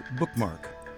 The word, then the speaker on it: BKMK: I invite you to send your unpublished book of poetry or short fiction to us at BKMK Press, pronounced Bookmark.